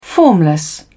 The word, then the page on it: formless: British English